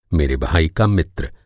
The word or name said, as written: का